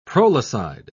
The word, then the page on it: prolicide: British English